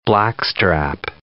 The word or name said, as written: blackstrap molasses